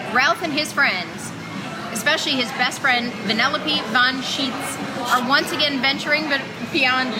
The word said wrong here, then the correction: Vanellope von Sweets: Did I say it wrong? – Sweets. – Sweets. – Sweets. – Sweets? – Yeah. Sweets. Vanellope von Sweets.